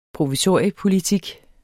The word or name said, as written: provisoriepolitik